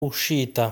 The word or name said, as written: Uscita